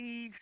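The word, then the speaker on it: Yves: Notice they get a nigga a name that sounds like a woman's name, even though they spell it Y-B-E-S, like Eve Saint Laurent.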